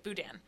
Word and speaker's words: Boudin: Yeah, I pronounced that incorrectly. It was embarrassing yesterday. Boudin.